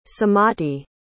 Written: sumati

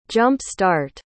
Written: jump-start

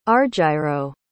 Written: argyro

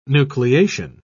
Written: nucleation

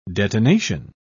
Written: detonation

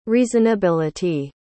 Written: reasonability

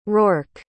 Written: Rourke

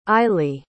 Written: ily

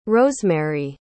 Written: rosemaree

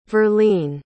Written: verlene

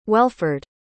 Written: Welford

